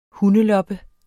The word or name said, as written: hundeloppe